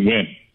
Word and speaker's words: Nguyen: It's spelled N-G-U-Y-E-N. I only know how to pronounce that because I had a coworker, but the same last name, pronounced it Nguyen with no N or G in the middle of the pronunciation.